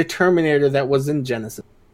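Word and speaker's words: Genisys: It's pronounced Genesis, but it was spelled like a child wrote it.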